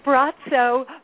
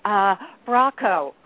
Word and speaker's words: Braco: Bratso, but it's spelled Bratso. So you look at his name and you would say Braco, I think in English, but Croatian, it's Bratso.